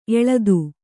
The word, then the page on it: ಎಲ್ಲಿತ್ತು: ellittu